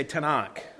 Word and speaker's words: Tanakh: Everybody say Tanakh. All right, a little more confidence on that. Tanakh. It's actually a three-letter acronym, T-N-N-K, with some vowels thrown in to make it easily pronounced.